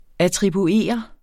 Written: attribueret

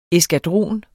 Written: eskadron

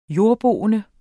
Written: jordboende

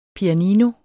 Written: PIANINOER

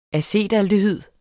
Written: acetaldehyd

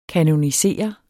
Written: kanonisere

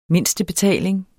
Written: mindstebetaling

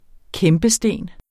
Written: kæmpesten

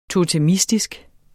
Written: totemistisk

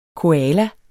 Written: koala

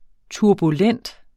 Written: turbulent

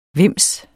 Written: vimse

